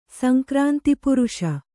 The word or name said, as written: ಸಂಕ್ರಾಂತಿಪುರುಷ